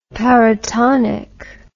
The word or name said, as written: paratonic